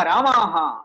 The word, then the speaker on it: रामः: पुल्लिङ्गे चेत् रामः दीर्घं कुर्मः चेत् रामा इति भवति अतः रामशब्द उच्चारणसमये श्रद्धा करणीया रामः वा रामा वा इति चिन्तनीयं दीर्घीकृत्य वदति चेत् महिला इति अर्थः